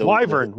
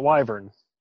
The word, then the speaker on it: Wyvern: What are the Wyvern. Wyvern. That's how you say it. Wyvern.